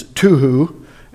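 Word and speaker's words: tohu: Formless is a Hebrew word that it's literally the way it's supposed to be pronounced is tuhu.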